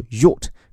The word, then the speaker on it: yacht: 注意这个 A 字母发的是 O，后面这个 ch 它不发音，所以叫做 yacht。